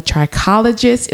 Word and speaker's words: trichologist: She talked about some very inspirational things that she went through as a hairstylist and how she became a trichologist.